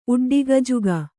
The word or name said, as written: ಉಡ್ಡಿಗಜುಗ